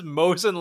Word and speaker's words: Mosin: Because he won't sell me his moisen. – Moisen? Mitch, who the fuck pronounces Mosin like Moisen? – Okay.